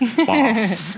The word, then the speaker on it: bawse: That's spelled B-A-W-S-E. And every time I try to say it, my daughter rolls her eyes and says, you can't say it properly. It's boss, boss.